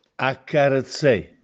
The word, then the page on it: Accarezzè: Castellanetano